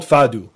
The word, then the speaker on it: fado: I so get it, because in my instance, my parents grew up on like old Portuguese, it's called fado, that's how you say it, but I didn't even know what the translation word is, but that's like old school.